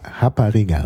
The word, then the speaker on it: Rapariga: Also Portugiesisch Rapariga, also ist ausgesprochen, das R wird ja wie H ausgesprochen im Brasilianischen, ist in Brasilien die Prostituierte.